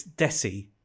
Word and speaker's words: Desi: So it's, first of all, it's Desi. – Oh. – Like it rhymes with Lionel Messi. – Okay. – So soft S's.